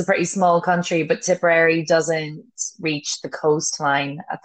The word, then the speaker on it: Tipperary: I'll put a link on our homepage, because if you're a horse enthusiast, you're really gonna love it, about the County Tipperary, the breeder. And tell me if I'm saying it wrong, but I'm just in love with this podcast. – No, Tipperary's correct.